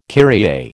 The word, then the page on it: Kyrie: American English